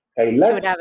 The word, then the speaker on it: Kailash: me tienen que ayudar con la, con la pronunciación del, del, del autor. – Kailash podría ser, ¿cierto? – Sí.